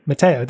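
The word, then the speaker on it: Mateo: Matteo, that's the one, that's how you pronounce it, yeah.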